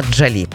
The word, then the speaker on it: Джолі: Ну, а у 1975-му американська акторка і просто красуня Анджеліна Джолі. Або Джолі, кому як більше подобається. Я чула правильний наголос і такий, і такий.